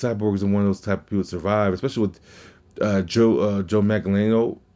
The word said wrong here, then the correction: Manganiello: or Magliano? Sofia Vergara's husband. His Deathstroke. I can't pronounce his last name. And Manganiello. There we go.